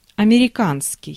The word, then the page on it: американский: [a.mɛ.ri.'kan.ski]; /ɐmʲɪrʲɪˈkanskʲɪj/; amerikanski